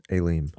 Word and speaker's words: Elim: In English, Elim. – Oh, E-L-I-M. Is how you transliterate it. – Okay. – Yeah, Elim. Mm-hmm.